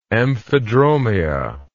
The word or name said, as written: amphidromia